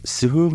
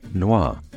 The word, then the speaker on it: Sureau Noir: Black Elder is known in French as Sureau Noir. It's pronounced Sureau, S-U-R-E, A-U, Noir, N-O-I-R.